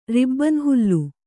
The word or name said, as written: ರಿಬ್ಬನ್‍ಹುಲ್ಲು